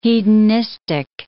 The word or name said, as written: hedonistic